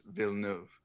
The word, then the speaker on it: Villeneuve: It's Villeneuve. – Fair enough. – Villeneuve.